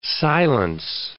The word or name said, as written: silence